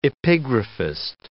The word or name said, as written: epigraphist